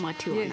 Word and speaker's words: Matiwana: Am I saying that right, girls, ladies? – Matiwana. – Yes. Matiwana, okay.